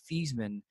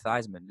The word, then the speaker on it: Theismann: And she was explaining to me how his last name is actually pronounced Theismann, but when he was up for the Heisman Trophy, probably back in like the 80s, he changed the pronunciation to Theismann.